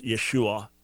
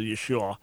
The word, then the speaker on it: Yeshua: Jesus in Greek, and then the spelling in the Peshitta and Syriac indicates that the right pronunciation is Yeshua. There would be a final ah, so Yeshua.